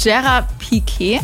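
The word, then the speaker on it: Gerard Piqué: Damals hat sie nämlich ihren Fußballstar, ihren eigenen kennengelernt, Gerard Piquet.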